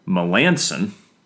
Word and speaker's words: Melancon: And then Mark Melancon.